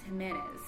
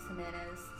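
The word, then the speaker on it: Jiménez: And the creator, his name is Luis Jimenez. If I pronounce that right, Luis Jimenez. – Jimenez. I'm assuming it's Jimenez.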